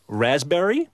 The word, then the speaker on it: raspberry: Raspberry is... – The silent S... – P. – Well, P. Sorry.